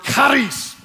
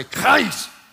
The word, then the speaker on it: charis: Well, the Greek word that's translated as grace is the word charis. Everybody say charis. You got to say it like you're hacking a loogie. Trust me, that's the way you say it.